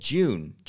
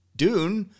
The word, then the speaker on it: Dune: and I said June. I don't know why June. It's just when I see the word Dune, my brain says June. But June, of course, is a month. It's not how you pronounce it.